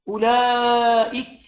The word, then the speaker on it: أولئك: أولئك، كل الحروف هنا، كل الحروف هنا مرققة. الواو واو لا تنطق، همزة، همزة وصل، همزة قطع ثابتة وصلا وابتداء. الواو لا تنطق، لا مد، لام، لام حرف مرقق وصلا، والمد مد متصل، مد متصل واجب 4 5 حركات وصلا ووقفا.